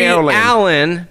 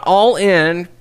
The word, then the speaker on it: Allin: Darby Allin. Not all in. It's spelled all in. I keep saying all in. It's Darby Allin and Joey Janela.